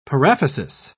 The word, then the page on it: paraphysis: British English